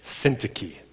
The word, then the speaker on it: Syntyche: But today, Euodia and Syntyche stop fighting. I Googled Syntyche, how to pronounce that. I said it like 12 times yesterday, about 30 times this morning. Syntyche.